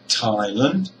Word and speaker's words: Thailand: The first one is Thailand. Here we have two syllables. The first syllable, Thai, is a strong syllable. It's a high-pitched syllable, and it is long and loud. The second syllable, Lund, is low. It's a weak syllable. It's also short.